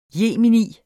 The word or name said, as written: jemini